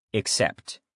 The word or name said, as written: except